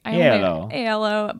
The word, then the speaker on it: Aiello: It's Danny Aiello. I don't know if I'm pronouncing that right. – Aiello.